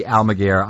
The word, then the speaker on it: Almaguer: I am here with Eddie Almaguer.